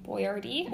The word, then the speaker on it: Boyardee: Boyardee.